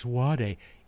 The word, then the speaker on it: vade mecum: It's a Latin phrase, vade mecum. I know some of you are like, it's vade. You pronounce Latin your way, I'll pronounce it my way.